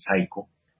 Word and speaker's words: psycho: y yo sé que esa palabra psycho es difícil de pronunciar, pero en realidad quiere decir psicópata, y se pronuncia psycho, como la Sociedad de Autores y Compositores.